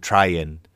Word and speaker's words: Trajan: So like, for example, we would believe that Trajan was probably actually called Traian. And, Hadrian would have been called Adrian. And it's just the difference in pronunciation.